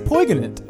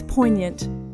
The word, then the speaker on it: poignant: It's poignant. – Yeah. It's not, though. It's not. It's poignant.